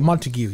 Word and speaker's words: Montague: Yeah, Montague.